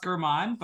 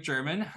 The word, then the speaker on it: German: Yeah, so my name is actually pronounced German, but a lot of people say it like German. – I'm sorry. – No worries at all. It happens all the time. That's the common pronunciation of, you know, that spelling.